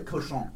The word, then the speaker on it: Cochon: Although there's a place in New Orleans called Butcher that is the same people that own Cosson? Cosson? – Oh, Cochon.